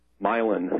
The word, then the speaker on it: Milan: I mean, you got to go to Milan, as they pronounce it here, 20 miles down the road, where there's some processing where they are.